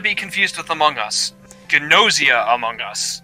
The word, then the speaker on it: Gnosia: Nausea. It's Gnosia. Not nausea, as in like you're sick. It's got a G in front of it. It's like pronounced like Gnostic. Gnosia, Among Us.